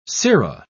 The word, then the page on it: sera: British English